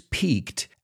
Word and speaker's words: pique: And it's interesting because it's spelled exactly like the soccer player, Piqué. But we pronounce it just like peak, like a mountain peak.